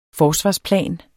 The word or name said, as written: forsvarsplan